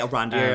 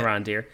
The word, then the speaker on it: Arondir: Iranadir there. Okay. Or did I say it right? I'd say Arondir. – Um, I think that's how you say it. Arondir.